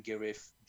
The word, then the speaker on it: Guerif: People want to reach out to me on Facebook, they can send me a message, Jan Gerif. – Okay, you want to spell that for them, if any advice? Yeah, Jan, Y-A-N, my last name, Gerif, G-U-E-R-I-F.